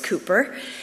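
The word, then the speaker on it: Cowper: His name is William Cooper. If you want to look him up, you spell his name K-O-W-P-E-R, but it's pronounced Cooper.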